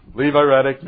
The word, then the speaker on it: Levirate: We'd say that with you if you'd say it right. Levirate marriage.